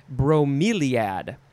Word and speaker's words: bromeliad: Next, we have bromeliad. No, that's not how you say it. Bromeliad. B-R-O-M-E-L-I-A-D. Bromeliad. Noun from 1866.